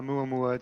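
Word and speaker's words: Amuamua (ʻOumuamua): is it Amuamua? Is that how you pronounce it? – Yeah, Amuamua. It's a Hawaiian-sounding name.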